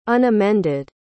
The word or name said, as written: unamended